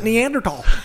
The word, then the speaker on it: Neanderthal: I am only 0.01% Neanderthal. – Yeah. Thank you for saying the word correctly.